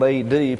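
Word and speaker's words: ad (עד): It's spelled A-D, pronounced ad.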